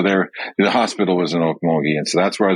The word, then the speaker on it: Okmulgee: I was born in Okmulgee, Oklahoma. – See? – My dad. – Who the hell knows how to pronounce Okmulgee? – That's right. Yeah, Okmulgee.